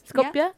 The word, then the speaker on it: Skopje: Welcome back from Skopje? How do you pronounce Skopje? – It? – Skopje? – Skopje? Skopje. Yeah.